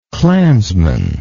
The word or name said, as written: clansmanship